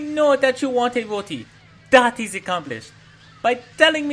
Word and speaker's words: roti: Oh, dear lord, what is a rot eye? It is a rooty. That's what we call it. You say rooty, understand? – How do you say it? – Rooty. R-O-T-I. Rooty.